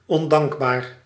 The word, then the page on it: ingrat: France; [ɛ̃.ɡʁa]